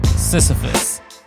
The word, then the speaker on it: Sisyphus: I think it's pronounced Sisyphus. Yeah, Sisyphus.